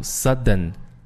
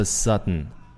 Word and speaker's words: sudden: 那么在这个地方要特别注意这个 sudden 这个单词的读音啊，如果你把 d 读出来可以没问题，就是读 sudden， all of a sudden，但是呢比较更地道的这种发音方式会把这个 t 呢这个 d 就给吞掉，读成是 all of a sudden， all of a sudden， OK，那么前面也是一样，把这个 all of 这三个单词连起来读叫 all of， all of，所以连起来叫 all of a sudden。